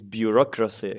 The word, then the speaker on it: bureaucracy: And I'm just checking this the pronunciation of the word. It's bureaucracy, actually. Bureaucracy.